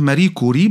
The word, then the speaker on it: ماري كوري: هي بتنطق ماري كوري بس بتحط كسره صغيره تحت الكاف تبقى حلو تبقى لطيفه لو عملت كده نطقها شبه دقيق